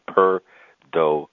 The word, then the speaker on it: Perdomo: So think about a cat purring for the first syllable. Think about Do Re Mi for the second syllable. And think about Mo Better Blues for the third syllable. So it would be Perdomo.